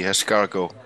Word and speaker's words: escargot: No, you don't say the tea, escargot.